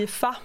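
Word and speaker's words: Aoife: Ja, det är ju IFA. Men det stavas ju Aufi. Men man säger IFA.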